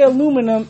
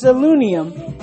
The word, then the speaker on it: aluminium: and if you may have noticed that Brits say they don't say aluminum, it's aluminium, and I don't know why. It took a long time for your boy to say aluminum as opposed to aluminium.